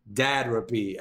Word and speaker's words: Dadrapy: You know, like therapy, but dads for dads. Dadrapy.